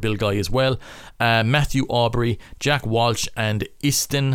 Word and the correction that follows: Iestyn: Hopkins. I hope i pronounced that name right. I think it's uh, Iestyn Hopkins.